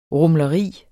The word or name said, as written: rumleri